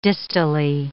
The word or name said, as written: distally